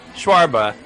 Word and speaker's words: shawarma: Shawarma, yeah. – No, shawarma. You said there was a silent H, so I'm overpronouncing. – Shawarma. Yeah. There we go.